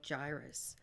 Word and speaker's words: gyrus: I don't know how to pronounce his case. G-Y-R-U-S. Gyrus? – Gyrus.